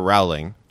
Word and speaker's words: Rowling: Wait, is it Rowling or Rowling? – It's Rowling, like rolling with the homies. – We said Rowling several times.